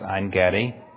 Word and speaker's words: Ein Gedi: David is in the wilderness at En Gedi." Sometimes it's pronounced Ein Gedi.